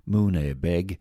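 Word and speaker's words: Muine Bheag: The townspeople voted to change the town's name to Munavyugh, pronounced Mune Beg, or Little Shrubbery in the 1950s, but many locals still refer to it as Bagnallstown.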